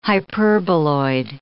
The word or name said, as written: hyperboloid